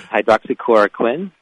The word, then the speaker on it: hydroxychloroquine: But hydroxychloroquine.